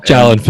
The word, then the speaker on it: Jalen: And then we've got that, is it Jalen Films or Yalen Films? I'm not sure if it's a silent J or not. – Jalen Films, yeah, Jordan Allen, yeah.